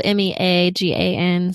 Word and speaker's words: Meagan: And my name is spelled Meagan. So my mom says it's the Southern way.